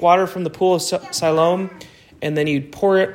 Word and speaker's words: Siloam: Siloam. – Siloam.